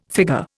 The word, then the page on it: figure: Indian